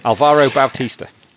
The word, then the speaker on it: Alvaro Bautista: Alvaro Bautista.